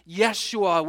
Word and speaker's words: Yeshua: The Old Testament version of this name is Joshua. It's pronounced Yeshua, which means Yahweh saves.